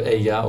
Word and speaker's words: Eja: One is called Eya or Asia. How do you spell that? – Eya E-J-A.